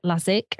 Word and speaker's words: Laczik: Is just correct me in the pronunciation of your surname. Tell us how we say your surname. – Laczik. – Laczik. Oh, okay. In my ears, that was the same, but it absolutely probably wasn't to you.